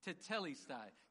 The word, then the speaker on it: tetelestai: In the Greek, it is pronounced tetelestai. Say that with me tetelestai.